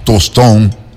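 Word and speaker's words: Tostão: Pelé, Rivelinho, Tostón. ¿Ah? Qué buen acento, ¿no? Se escribe tostao, pero dices tostón, ¿no?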